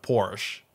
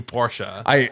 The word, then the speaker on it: Porsche: I've noticed you do the German pronunciation of Porsche. Right, like you do Porsche. – The only reason is because I actually thought it was just Porsche until I heard a bunch of Germans say it, actually how you say it.